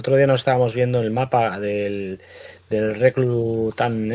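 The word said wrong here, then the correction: Reluctant: Enemies. Reluctan. Reluctan, joder, siempre lo digo mal.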